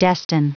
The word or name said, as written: destine